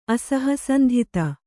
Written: ಅಸಹಸಂಧಿತ

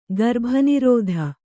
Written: ಗರ್ಭನಿರೋಧ

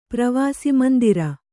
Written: ಪ್ರವಾಸಮಂದಿರ

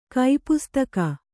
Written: ಕೈಪುಸ್ತಕ

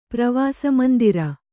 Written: ಪ್ರವಾಸಮಂದಿರ

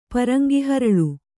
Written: ಪರಂಗಿಹರಳು

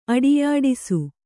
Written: ಅಟ್ಟೆಯಾಡಿಸು

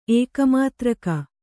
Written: ಏಕಮಾತ್ರಕ

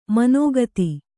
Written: ಮನೋಗತ

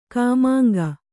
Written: ಖಮಂಗ

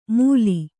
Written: ಮೂಲಿ

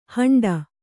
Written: ಹಂಡ

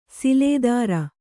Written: ಸಿಲೆದಾರ

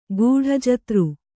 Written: ಗೂಢಜತ್ರು

